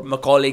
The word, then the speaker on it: Macaulay: Am I pronouncing the word Macaulay yes, rightly? Yes, yes.